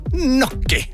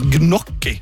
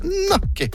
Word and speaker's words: gnocchi: Fra en stolt italiener som elsker gnocchi. – Og var det uttalen min som var Ja, de heter ikke gnocchi. Det uttales gnocchi. Med en sånn liten sånn over en. – Gnocchi.